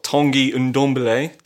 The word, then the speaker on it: Ndombele: Okay, so as mentioned, 2019 was the year Spurs opened their new 60,000-seater state-of-the-art stadium, broke their club transfer record for Tanguy Ndombele. Dan, have I pronounced that right? – Correct.